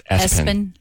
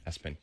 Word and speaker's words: ESPN: Somebody who's really into sports named their child ESPN. Pronounced Espen. – Espen, yeah.